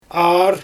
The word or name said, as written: aar